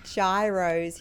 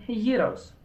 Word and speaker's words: gyros: He said, sorry, my question is very food related today, but he wants to know, is that how you pronounce it? Gyros? – Euros. – Euros. I'm like, gyros. – That's so American.